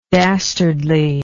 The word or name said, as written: dastardliness